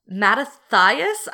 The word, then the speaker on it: Mattathias: So in 166, not 16-6 BCE, Mattathias, I believe is how you say his name. – That sounds right to me.